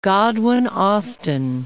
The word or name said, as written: Godwin-Austen